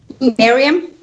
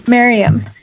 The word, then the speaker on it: Mariam: It's kind of a uh, false reason though because uh, as how do I say your name again? I'm sorry. Miriam. Miriam.